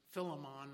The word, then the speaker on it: Philemon: And now he would be useful far beyond anything that Philemon, his master, had dreamed. By the way, if you want to pronounce his name Philemon, that's okay too.